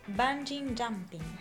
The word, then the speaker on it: bungee jumping: Bungee jumping. ¿Lo dije bien? Lo dijiste bien, sí.